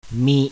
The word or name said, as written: MI